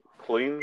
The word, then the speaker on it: Klein: Ludovic Klein, I think. Klein, yeah. That's how you say it.